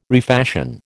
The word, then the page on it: refashion: American English